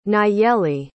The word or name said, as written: nayely